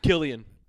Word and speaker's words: Cillian: Killian, is that how it's pronounced? – Yep.